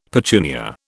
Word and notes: petunia: British English

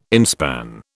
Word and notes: inspan: British English

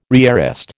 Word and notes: rearrest: American English

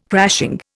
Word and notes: crashing: Indian

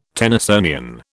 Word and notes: Tennysonian: British English

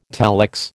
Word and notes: telex: American English

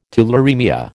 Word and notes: tularaemia: American English

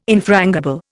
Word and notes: infrangible: Indian

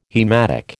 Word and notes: haematic: American English

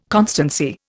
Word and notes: constancy: Indian

